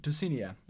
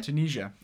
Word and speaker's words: Tunisia: I realized that my character was really George Lucas when we were filming in Tunisia. I'm pronouncing that Tunisia. – Wrong. Tunisia, of course, yeah.